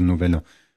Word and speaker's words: Novelle: Und das kurze heißt aber auch Novelle. Also mit L-E, aber anders ausgesprochen. Also Novelle.